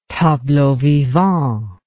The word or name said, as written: tableau vivant